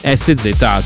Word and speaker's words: SZA: adesso la pronuncia precisa di quest'artista bravissima, è una ragazza, SZA, SZA,